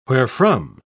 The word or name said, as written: wherefrom